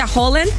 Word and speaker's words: Haaland: Pues no, sí, justamente que muchos les dicen Haaland, pero ya nos dijeron que se pronuncia Haaland.